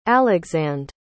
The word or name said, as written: alexande